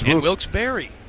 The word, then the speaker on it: Wilkes-Barre: And we have just been informed that that is the correct pronunciation of that city. I think I called it Wilkes-Barre, but I think you can call it Wilkes-Barre. – It's Wilkes-Barre. – Wilkes-Barre, yeah.